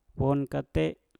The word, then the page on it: wom kate: mpur